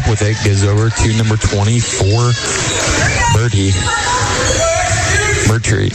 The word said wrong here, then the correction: Beatrice: How do you say that? 24. – Beatrice. Yep.